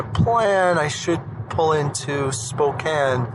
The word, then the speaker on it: Spokane: So I am now driving en route to Spokane, Washington, I've been saying Spokane the whole time, and Grandma told me I got to say it right, and Spokane is supposedly the right way.